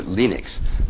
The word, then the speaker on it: Linux: Actually, I think he calls it Linux because his name is Linus. He pronounces that way.